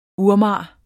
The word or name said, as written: urmager